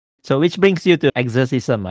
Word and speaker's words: Exercism: You pronounce it fine. So I would say Exercism.